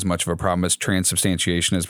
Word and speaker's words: transubstantiation: It's certainly not transubstantiation.